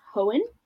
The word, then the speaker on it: Hoen: I believe it's pronounced Hoen, H-O-E-N.